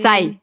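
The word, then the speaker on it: Psy: nunca he podido pronunciar bien, Pisai, P-S... – Sai, Sai. – Sai, ya.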